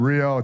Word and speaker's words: Rio: I hope that you don't either, but it goes something like this, that a butterfly flapping its wings in Rio, if you're from Rio, it's actually pronounced Rio, Rio de Janeiro in Brazil.